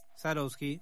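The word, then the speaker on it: Swarovski: Sarovsky, literalmente.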